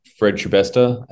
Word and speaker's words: Shibesta: Well, my name's Fred Shibesta. As it's pronounced Shibest Star, like the best star.